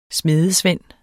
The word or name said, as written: smedesvend